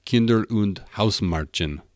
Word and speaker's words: Kinder und Hausmärchen: But we jump ahead a bit to the Brothers Grimm, about 100 years later, and their collection was literally called Kinder und Hausmärchen. Does that sound German? Did I say that right, do you think? – German, I need to be more guttural.